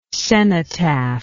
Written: cenotaphic

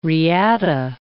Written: reata

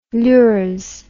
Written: lures

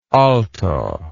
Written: aalto

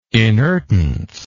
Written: inertance

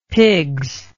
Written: pigs

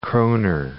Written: kronur

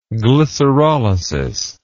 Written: glycerolysis

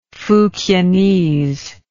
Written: fukienese

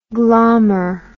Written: glahmer

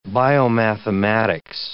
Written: biomathematics

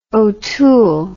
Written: otoole

